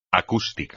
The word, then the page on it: acústico: espagnol